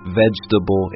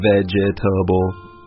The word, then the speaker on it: vegetable: I guess this word could be challenging because there's four syllables when you look at it, but when we pronounce it, we say it much quicker, so it just sounds like vegetable instead of vegetable.